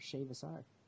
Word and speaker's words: Shea Vassar: And this week, we're joined by writer Shea Vassar.